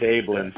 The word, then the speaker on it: Abeln: It's Ablin. – Ablin. – And it's spelled A-B-E-L-N.